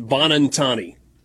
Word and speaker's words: Buonantony: It's spelled B-U-O-N-A-N-T-O-N-Y, and it's pronounced Bonantani.